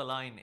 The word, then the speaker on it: Lyne: Tessa Line is my name.